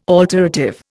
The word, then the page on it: alterative: Indian